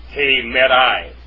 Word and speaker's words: hemerai: Actually, that could be hemerai, because it's got an alpha iota subscript. And when that happens, it's pronounced like a diphthong, like alpha iota, which is I. Hemerai.